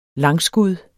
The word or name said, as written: langskud